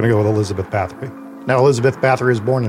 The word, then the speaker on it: Bathory: Now, we are going to be using the English pronunciation of her name, which is Elizabeth Bathory, much to the chagrin and anguish of my co-host, Jesse.